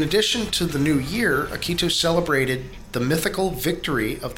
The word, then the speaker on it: Akitu: They marked the occasion with a massive religious festival called Akitu. Am I pronouncing that properly? – Akitu? – Akitu? – Akitu? – A-K-I-T-U.